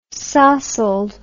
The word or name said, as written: sossled